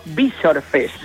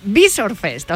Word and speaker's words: Visor Fest: No, Yabres me dijo Visor Fest, no Visor. – Vale, Visor Fest, o sea, con el acento en la I.